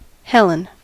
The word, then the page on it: Helen: /ˈhɛlən/